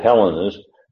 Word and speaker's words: Hellenists: And the reason I said Hellenists, that would be the pronunciation of the Greek form.